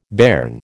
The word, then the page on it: bairn: American English